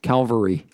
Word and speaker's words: Calvary: Calvary. Yeah, if you put the L in the wrong place, you got a bunch of guys on horses.